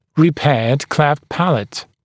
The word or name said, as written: repaired cleft palate